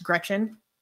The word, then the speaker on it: Greczyn: It's spelled G-R-E-C-Z-Y-N, but it's pronounced Gretchen.